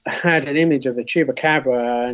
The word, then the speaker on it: Chupacabra: Chupacabra.